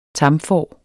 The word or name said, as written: tamfår